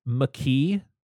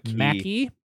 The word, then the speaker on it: McKee: And so I just want to address the pronunciation of it's McKee, not Mackie.